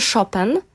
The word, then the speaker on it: Chopin: Dlatego, że jeżeli pisze się Chopin, prawda, przez CH pisze się Chopin, a czyta się Chopin, a właściwie Francuzi, ponieważ jest to francuskie nazwisko, Francuzi mówią Chopin, Fryderyk Chopin.